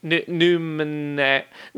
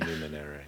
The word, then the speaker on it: Numenera: Numenera. That does look right. – Numenera, yeah.